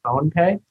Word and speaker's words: PhonePe: And then one interesting asset Flipkart owns that I didn't know about is PhonePe.